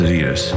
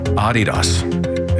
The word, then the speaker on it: Adidas: It's not pronounced Adidas. Mm-hmm. We're the only people in the world that pronounce it Adidas. – Okay. – Adidas is how to say it. Adidas. Because the guy who founded it is a German guy named Adi Dassler.